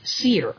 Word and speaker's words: Seir: Okay, it's actually Mount Seir. – Mm-hmm. – Okay, that's spelled S-E-I-R. And so whenever you see Seir, S-E-I-R, is going to be speaking about Esau's basically kingdom that he had and that he built and the people of that.